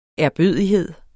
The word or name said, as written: ærbødighed